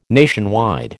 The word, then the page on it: nationwide: American English